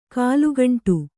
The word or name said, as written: ಕಾಲುಗಂಟು